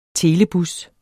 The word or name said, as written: telebus